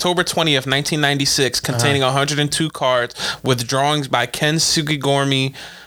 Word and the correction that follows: Ken Sugimori: Ken Sugimori.